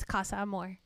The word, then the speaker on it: Casa Amor: So the guys got split from their girls and they were sent to a place called Casa Amor. Yes. – Casa Amor. – Casa Amor. – It's a love house. It's Spanish, babe. It's Casa Amor.